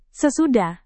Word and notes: sesudah: Indonesian

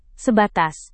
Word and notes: sebatas: indonesian

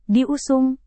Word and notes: diusung: Indonesian

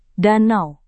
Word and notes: danau: Indonesian

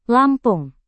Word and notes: lampung: indonesian

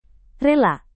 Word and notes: rela: indonesian

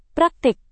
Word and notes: praktik: Indonesian

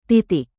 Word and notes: titik: Indonesian

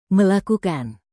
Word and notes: melakukan: Indonesian

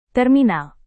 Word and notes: terminal: indonesian

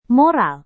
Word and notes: moral: Indonesian

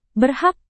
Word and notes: berhak: Indonesian

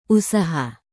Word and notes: usaha: indonesian

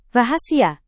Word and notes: rahasia: Indonesian

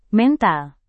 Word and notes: mental: Indonesian

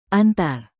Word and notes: antar: indonesian

